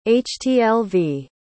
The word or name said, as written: htlv